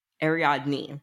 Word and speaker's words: Ariadne: Ariadne. Yeah. Not nay. – Try to put it next to the next time you read it. – So Ariadne. Did I spell it wrong? No, it's Ariadne. – No, it's spelled right. I was just going to phonetically put it there for you. – Knee. Knee, not nay.